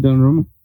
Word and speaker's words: Donnarumma: Yeah, and um, Doname- how do you say his name? – Gigi Donnarumma.